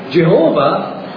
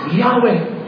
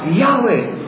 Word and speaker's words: Yahweh: Yung the words W-H-W-H na mas kilala natin Jehovah, but most likely a mistranslation, but most likely the closer is Yahweh. Yahweh.